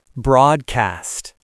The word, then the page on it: broadcast: American English